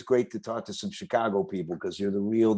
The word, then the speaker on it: Chicago: And I said Chicago in front of some people the other day. They said, you said it right.